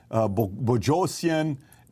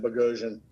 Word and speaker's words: Boghossian: Yeah, I tell everybody it rhymes with explosion, just to make it easy. Explosion, Boghossian.